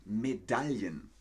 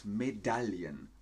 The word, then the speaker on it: Medaillen: Dann gibt es natürlich auch noch eine sehr wichtige Vokabel für die Olympischen Winterspiele, die Medaillen. Medaillen. Medaillen. So spricht man das aus. Medaillen. Nochmal. Medaillen die Goldmedaille, die Silbermedaille und auf Platz 3 ist die Bronzemedaille.